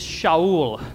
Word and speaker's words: Shaul: His Hebrew name is Shaul. Let me hear you all say Shaul. Excellent job. That's how Saul was pronounced in Hebrew or Aramaic, Shaul.